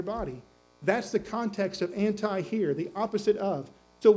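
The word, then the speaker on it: anti (ἀντί): In the Greek, it's pronounced anti. It's spelled A-N-T-I. Anti is how we would pronounce that.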